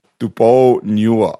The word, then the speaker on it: Tuponiua: Sitili. See? – Sitili. – No, Sitili. – Sitili. – Tuponiua. – See, your pronunciation's off. That's why I can't spell them. – Sitili Tuponiua. – Sitili. S-I-T-I. – Yes. – L-I. – Yes. – T-U-P-O-U. – Yes. Niua. – N-I-U-A. – Yes.